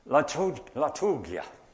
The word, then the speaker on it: liturgia: It's liturgia. That's the way I was told to pronounce it in college. Actually, I've met people who pronounce it differently now, but never mind.